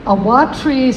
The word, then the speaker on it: Awatere: And then further down, there's one that starts with A, that practically, I've heard now five different pronunciations for this, from Awatere to Awatri. Awatri seems to be the one that New Zealanders say most often, which makes no sense to me.